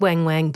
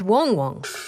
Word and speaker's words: Wang Wang: But no, it's spelt Wang Wang, but it said Wong Wong.